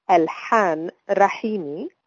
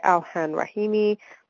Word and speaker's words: Alhan Rahimi: My name is originally an Arabic name, and the proper pronunciation or the Arabic original pronunciation of it is Alhan Rahimi. Because it's not very easy to pronounce that deep H sound, that's why I go by Alhan Rahimi when I'm speaking to English-speaking people.